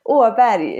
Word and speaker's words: Åberg: Is how we say it over here in Canada. Thank you for spending your, Sunday evening, spending a few minutes chatting with us. Why don't you say your name in Swedish just because it sounds cool? – Åberg is my name in Swedish, like last name.